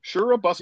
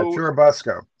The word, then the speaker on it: Churubusco: And so thousands of people have made their way to this town of, go ahead, if you want to pronounce it, Churubusco. Churubusco. – Yeah, Churubusco.